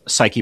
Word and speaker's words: Psyche: So this book talks about effective teams using your life experience, in particular, the launch of NASA's Psyche mission. Am I pronouncing that correctly? – Yeah, that's how we pronounce it anyway.